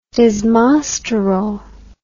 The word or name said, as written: desmosterol